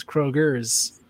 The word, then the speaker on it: Kroger: And first of all, if you're going to say it, you have to say it like you're from Wheeling. And that means it's not Kroger. It is Kroger's. – Kroger's. Put that S on there.